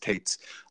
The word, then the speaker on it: Toetz: It's spelled T-O-E-T-Z. Apparently it's pronounced Tates.